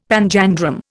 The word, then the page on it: panjandrum: Indian